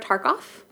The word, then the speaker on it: Tarkoff: It's at Sarah Tarkoff.